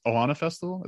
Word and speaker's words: Ohana Festival: it's Ohana Festival, is that what it's called, in Cali? – Yeah.